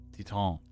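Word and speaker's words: Titane: But yeah, well, the new movies are Killing of a Sacred Deer, and Titan. Is that how you pronounce it? Titan? – Titan. – Is it Titan? – Titan. – Titan, French. Oh, yes.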